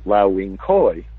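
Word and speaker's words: Lau Wing Kui: We're talking about Lau Wing Koi, which is pronounced differently than it's spelled, K-U-I.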